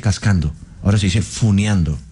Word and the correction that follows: funando: Sí, funando, me aclaran.